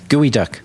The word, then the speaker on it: Geoduck: Yeah, Gooey Duck. – It's because it's spelled Geoduck. – Right, but pronounced Gooey Duck.